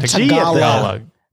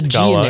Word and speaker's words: Tagalog: You say it like Tagalog. There's a Tagalog. G in the end. Tagalog. – Like a Tagalog. Like there a you log? Go. Like a log, yeah.